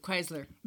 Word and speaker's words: Chrysler: Chrysler, Sí, Chrysler.